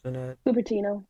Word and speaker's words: Cupertino: Cupertino? – Cupertino.